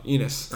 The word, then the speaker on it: Enes: But then the other most interesting duo to me is Kemba Walker and Enes Kanter because Kemba Walker is awesome, and Enes Kanter looks like me. So, Penis Kanter? – No, Enes.